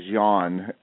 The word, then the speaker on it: Jean: I think is how you say his name is Jan, J-E-A-N, Jan Segura.